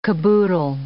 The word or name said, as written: caboodle